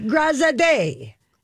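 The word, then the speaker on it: Graziadei: And it's Joey Grazade. – Joey Grazade. – Grazade. – You're going to always pronounce his last name.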